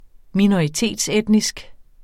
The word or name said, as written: minoritetsetnisk